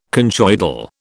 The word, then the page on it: conchoidal: British English